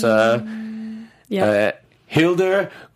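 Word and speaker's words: Hildur Guðnadóttir: Hilder Gudnadottir. – Oh, you did a lot of work to pronounce Hilder Gudnadottir. Gudnadottir. Hilder Gudnadottir. – Yep. Okay, so nailed it. – Hilda Gudadotnir.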